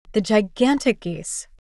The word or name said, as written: The gigantic geese